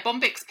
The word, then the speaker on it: Bombyx: Yeah, yeah, Bombyx PLM.